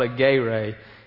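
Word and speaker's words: agape: It's not a yippee or whatever it looks like. That's not how you pronounce it. It's called a gay ray.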